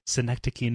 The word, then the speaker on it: Synecdoche: Synecdoche, New York.